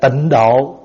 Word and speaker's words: Tịnh Độ: Người Hoa ta viết chữ thổ, nhưng mà mình người Việt đọc ra chữ độ. À, thí dụ như Tịnh Độ, đúng viết là viết Tịnh Thổ, nhưng mà đọc là đọc Tịnh Độ. Cho nên có nhiều vị sau này người ta đọc cho đúng âm là tịnh thổ.